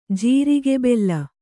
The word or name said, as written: ಜೀರಿಗೆಬೆಲ್ಲ